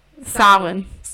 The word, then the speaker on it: Samhain: Samhain. So like sow, like a female cow, and then it's like an E-I sound.